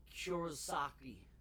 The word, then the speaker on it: Kurosaki: Ichiro Kurosaki.